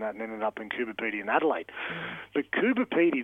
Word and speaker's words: Coober Pedy: Went there last year and was shocked that it's Coober Pedy and not Coober Pedy. I feel like we've all spent our whole lives saying it wrong.